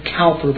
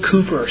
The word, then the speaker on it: Cowper: Sometimes people pronounce his name Cowper, but that's mistaken. William Cowper, C-O-W-P-E-R.